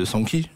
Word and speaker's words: Sanky: Il y a un vrai travail sur les voix, pour le coup, de Sanky, ça se prononce comme ça. – Sanky, ouais.